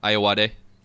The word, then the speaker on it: Ayoade: Ayoade.